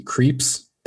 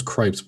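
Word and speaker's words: Krieps: Vicky Creeps. That is how her name is pronounced. I always thought it was Cripes, but it's Creeps.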